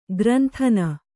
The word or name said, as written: ಗ್ರಂಥನ